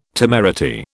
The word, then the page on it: temerity: British English